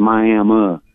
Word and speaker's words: Miami: We got a town over here that's spelled the same way as Miami, Florida, but we pronounce it Miami.